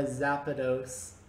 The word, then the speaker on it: Zapdos: You're more legendary than a Zapdos.